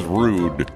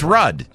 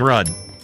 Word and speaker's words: Rudd: I thought it was Rude. – Is that how you say it? It's Rudd.